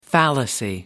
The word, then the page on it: fallacy: American English